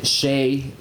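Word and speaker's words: Hsieh: She's got a very tough first round as well against Shea. That's how I pronounce it.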